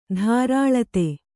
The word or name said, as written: ತರಳಿತ